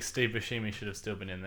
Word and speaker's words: Buscemi: I think it's Buscemi.